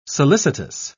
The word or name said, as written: solicitous